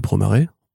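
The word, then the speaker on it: Promare: Le studio du Wasa ou euh, effectivement le studio de Promaré. On dit pas Promère du coup, c'est marrant parce que beaucoup de gens disent Promère.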